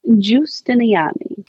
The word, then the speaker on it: Giustiniani: Giustiniani.